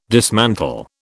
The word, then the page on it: dismantle: British English